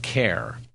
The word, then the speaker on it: Cahir: It's spelled C-A-H-I-R. His name is pronounced Kerr.